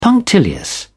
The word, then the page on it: punctilious: British English